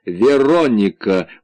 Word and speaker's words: Вероника: — Ты неправильно произносишь свое имя. Вероника! — он сделал ударение на «о» от слова «Верона».